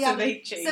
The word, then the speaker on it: ceviche: I had it right the ceviche.